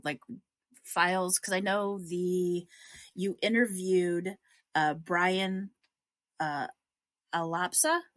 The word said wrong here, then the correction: Alaspa: Is that how you pronounce his name? Alapsa, the guy who wrote the book on Precious Hope? – Alaspa. – Alaspa, sorry.